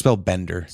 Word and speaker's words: Spellbender: I thought Spellbinder was a minion. – Mage secrets. Yeah, I might be saying it wrong. It's... Because there's Spellbinder, there's Counterspell. It's Spellbender.